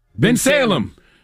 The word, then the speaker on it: Bensalem: Bensalem.